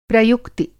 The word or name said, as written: प्रयुक्ति